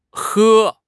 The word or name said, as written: he1